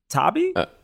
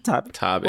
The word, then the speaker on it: Taabe: How do you say that? Tabi? T-A-A-B-E? – I think so. – Tabi.